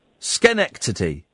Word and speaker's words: Schenectady: Schenect, sh, is it sh, is it not Schenectady? Oh, it's a ch, no, it's a ch. Um, Connect. Is it? Oh well, in that case, I've been pronouncing it wrong. Schenectady.